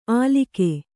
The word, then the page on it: ಆಲಿಕೆ: kannada